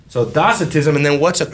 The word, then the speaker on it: docetism: Docetism. – That'd be more accurate because the Greek word is kappa, pronounced as a K.